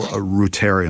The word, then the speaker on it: reuteri: reuteri.